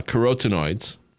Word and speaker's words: carotenoids: Um, all the vitamin A uh, in green gold is in the form of beta carotene and mixed carotenoids. There are over 600 uh, carotenoids did I pronounce that right, doctor? Carotenoids. Carotenoids. There it is.